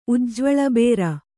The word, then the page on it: ಉಜ್ಜ್ವಳಬೇರ: Kannada